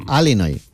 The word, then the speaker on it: Illinois: Se pronuncia Illinois. – Sí, perdón, perdón, sí.